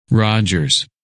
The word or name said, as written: Rogers